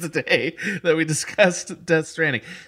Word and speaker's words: Death Stranding: That's right, Death Stranding, because every character pronounces it with that emphasis.